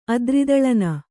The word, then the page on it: ಅದ್ರಿದಳನ: Kannada; adridaḷana